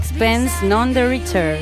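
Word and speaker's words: Sixpence None the Richer: ¿Lo he dicho bien, hija? – Bastante bien. – Bastante bien. – Sí. – A ver cómo lo dirías tú. Sixpence. A ver, la anglófona. – Sixpence, none the richer.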